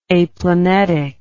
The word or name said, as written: aplanetic